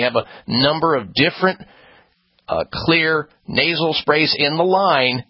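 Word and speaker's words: Xlear: And clear is spelled with an X, X-L-E-A-R.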